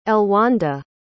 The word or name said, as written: elwanda